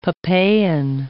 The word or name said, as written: papain